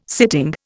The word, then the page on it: sitting: Indian